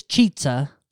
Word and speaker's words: Chizza: I think it's Cheetza. Because it's supposed to rhyme with pizza, isn't it?